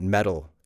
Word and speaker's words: meddle: It's spelled M E D D L E, and it sounds just like mettle.